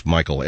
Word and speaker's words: Makel: It's Michael. M-A-K-E-L. Yeah, it's just something I do. It's pronounced Michael? Yes. And how do you spell M-A-K? Yeah, M-A-K-E-L.